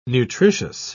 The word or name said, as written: nutritious